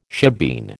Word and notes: shebeen: American English

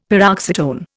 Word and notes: paroxytone: Indian

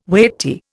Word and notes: weighty: Indian

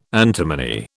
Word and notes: antimony: British English

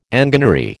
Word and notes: enginery: American English

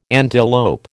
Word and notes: antelope: American English

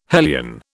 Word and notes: hellion: British English

